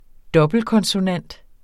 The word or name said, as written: dobbeltkonsonant